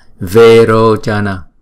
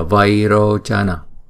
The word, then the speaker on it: vairochana: Chữ vairochana sẽ được phát âm là verochana thay vì là vairochana.